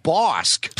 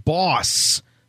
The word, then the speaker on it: Bosk: Is his beleaguered CEO, Bosk. – No, I think he said boss. No K. No K. The K is silent.